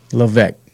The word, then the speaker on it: Levesque: It's Levesque. The S is silent. – Levesque.